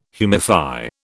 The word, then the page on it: humify: British English